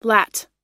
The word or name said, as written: lat